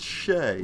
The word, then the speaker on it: Shai: Anyway, some other picks that I think, obviously, Shai, who I think he did pretty well. Am I pronouncing that right? SGA? – It's Shea.